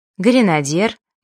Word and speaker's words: гренадер: Если бы при написании «е» от «ё» отличали, то мы бы точно знали, что правильно говорить «гренадер», «бытие» и «афера». Произношение этих слов через «ё» — весьма распространенная ошибка.